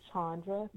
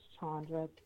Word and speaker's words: Chandra: I'm also on Facebook, and since I have a very long last name, I'm shortened my name on Facebook to Chandra B. Payne. So my Facebook name is Chandra B. Payne. – Awesome, awesome. And Chandra is spelled C-H-A-N-D-R-A.